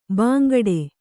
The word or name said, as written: ಬಾಂಗಡಿ